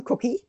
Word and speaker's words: Kucki: Anyway, yeah, my name is pronounced just like the edible one, Cookie. But it's spelled K-U-C-K-I.